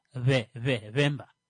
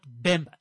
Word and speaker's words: Bemba: It's it starts with a B, but the B is pronounced with a softer lip contact. Vemba. Not Bemba.